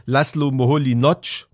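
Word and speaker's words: László Moholy-Nagy: wie man den ausspricht, Laszlo Moholy-Nagy, geschrieben Moholy-Nagy, so ein ungarischer Name.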